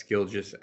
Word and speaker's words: Gilgeous: Yes, Gilgis Alexander. – Oh, it's a soft G? – Yeah. – Gilgis? – Yes. – Gilgis Alexander?